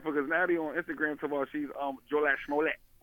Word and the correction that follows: Jussie: She's Jussie Smollett.